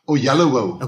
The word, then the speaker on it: Oyelowo: It's just Oyelowo.